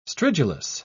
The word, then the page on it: stridulous: British English